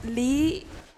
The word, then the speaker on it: Le: In Vietnamese is pronounced Le, but through the Western side of things, it's Lee.